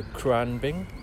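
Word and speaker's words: Khruangbin: Yeah, it's like it's K-H-R-U-A-N-G-B-I-N. So Kurunagrigibit.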